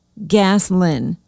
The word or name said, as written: Gaslyn